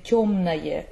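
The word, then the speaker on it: тёмное: And tёмное as well, it's spelled O in the end, but it's spelled, but you pronounce A.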